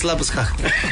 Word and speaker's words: Labuschagne: In Australia, Jake Weatherald could make his debut in the first Ashes test, while Australia have also recalled Marnus, how should we say this, like the proper way, Labuschagne for the series of... – Labuschagne. – It's not how he says it. – Labuschagne. Even he says Labuschagne.